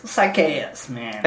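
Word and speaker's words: Sychaeus: Sichaeus? – Sichaeus. – That's exactly what I just said. – No, you said Sichaeus. Sichaeus, man.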